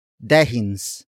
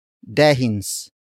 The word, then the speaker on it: dehins: Dehins ako umiinom. Dehins ako umiinom.